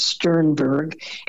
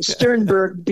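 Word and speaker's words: Sternburg: My last name is Sternberg, and it is spelled, the last whatever part of it, B-U-R-G.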